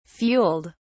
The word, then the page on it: fueled: British English